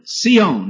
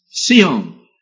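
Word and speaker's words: Sihon (ציון): Zion, Sihon, in the Hebrew it's pronounced. Sihon.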